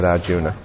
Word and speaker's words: Arjuna: You know, in pronunciation in Sanskrit and Hindi, generally if there's an A on the end, it's left off. So although we say Arjuna, in India you'll hear most people, they'll say Arjun.